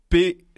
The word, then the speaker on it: Peilz: Paix, ça se dit Paix, mais ça s'écrit P-E-I-L-Z.